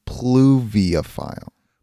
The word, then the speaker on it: pluviophile: Was that not poolophile? – Jeez. If anything, Craig, I know for a fact you're pronouncing it wrong, but I'll pull it up for you. – Okay. – Obviously, they don't have a pronunciation for some reason. Pluviophile. – P- Pluviophile. Okay.